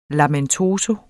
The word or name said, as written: lamentoso